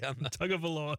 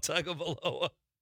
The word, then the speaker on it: Tagovailoa: Now she's hanging with the Kardashians and dating NFL quarterbacks like how do you say his last name? – Tagovailoa. – I don't think Chad said it right. – Tagovailoa. – Tagovailoa.